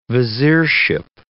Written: viziership

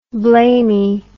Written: blamey

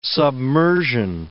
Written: submersion

